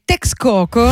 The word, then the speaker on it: Texcoco: E ci restiamo ancora un poco, sempre con il featuring della Bruja de Texcoco, questa è la pronuncia giusta a quanto pare, insieme a Los Pream.